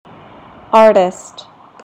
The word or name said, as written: artist